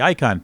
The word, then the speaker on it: Ikon: Then there's the Icon, that's spelled I-K-O-N, pass, the Icon Pass.